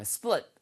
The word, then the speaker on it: split: 그래서 S-P-L-I-T 발음 주의하세요. 예, split, split 해서 splitting.